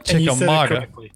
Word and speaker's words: Chickamauga: On Lake Chickamauga.